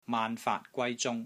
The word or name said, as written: MAAN6 FAAT3 GWAI1 ZUNG1